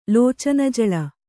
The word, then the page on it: ಲೋಚನಜಲ: Kannada